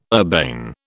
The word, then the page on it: urbane: British English